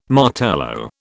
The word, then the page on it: Martello: British English